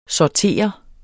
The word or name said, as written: sortere